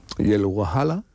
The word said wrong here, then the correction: Hawala: creo que se dice así. – Sí, la Hawala. – Hawala.